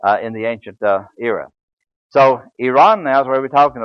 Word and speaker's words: Iran: They don't call it Iraq and Iran, as our news commentators do. It's Iraq and Iran. They pronounce the I as an E.